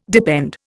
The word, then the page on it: depend: Indian